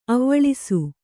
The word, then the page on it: ಅವ್ವಳಿಸು: avvaḷisu